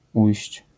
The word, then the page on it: ujść: język polski